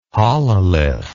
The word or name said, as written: hololith